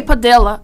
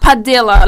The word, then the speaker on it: Padilla: People say Padilla. Because, like, Padilla, like, because it's spelled P-A-D-I-L-L-A.